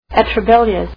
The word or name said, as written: atrabilious